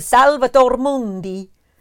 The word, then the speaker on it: Salvator Mundi: It is Leonardo da Vinci's Salvator Mundi.